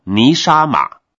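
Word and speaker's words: 尼 沙 玛 (נשמה): 这个要素在希伯来文读作尼沙玛，因为中文的气字并不足够表达原文的意思，我宁可直接用它的希伯来原文尼沙玛。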